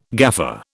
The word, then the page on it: gaffer: British English